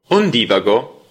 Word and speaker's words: Ondivago: Ondivago, percorsi ondosi attraverso la letteratura, le arti e la cultura, un programma ideato e condotto. – Benvenuti ad Ondivago. Ondivago, con l'accento sulla I